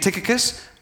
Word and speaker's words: Tychicus: Tychicus,